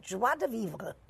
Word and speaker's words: joie de vivre: Dr. Ruth on Love, Life, and Joie de Vivre. I can never say that right. – You say it almost right. Joie de vivre.